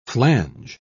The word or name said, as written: flange